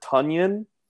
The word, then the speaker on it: Tonyan: Robert Tonyan, report came out that his name is actually Robert Tonyan. It rhymes with Funyan.